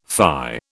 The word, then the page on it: fie: British English